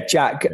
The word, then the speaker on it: JAAQ: Yeah, Jack. But it's not spelt like it sounds. So it's no. J-A-A-Q. Which you pronounce Jack.